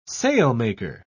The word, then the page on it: sailmaker: British English